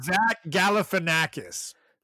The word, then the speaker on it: Galifianakis: Yeah, there is an L sound in there a little earlier. Galifianakis? – How do you say it? – Now I can't say it because of the way you say it. – Galifianopoulos. Is it Greek? – It's Zach Galifianakis.